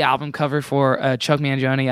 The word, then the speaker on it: Mangione: Chuck Mangoin. – Wait, pass him more. I can't. He looks like Jimi Hendrix in a hat. – Chuck Man, what? How the hell do you pronounce Mangione? – Mangione.